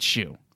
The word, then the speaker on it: Hsu: S as in Serald, and then U as in you gotten to be kidding me, and HSU, but he pronounces it, somebody pronounces it Hsu. – Yes. – It's a bunch of nonsense. – Anytime he's mentioned in the... media or interviews or anything, it's always Hsu.